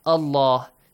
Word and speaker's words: Allah: The letter Lam in the word Allah and Allahumma must be pronounced heavily with an A sound in case we have a Fatha sound or a Dhamma sound before the word.